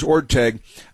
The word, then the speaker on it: Orteig: the name is Orteig. The truth is, in the Spirit of St. Louis, the great movie classic Spirit of St. Louis with Jimmy Stewart as Charles Lindbergh, they also mispronounced the name Orteig in that movie with Jimmy Stewart, but here is being mispronounced last night on Jeopardy as well.